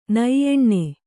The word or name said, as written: ನೈಯೆಣ್ಣೆ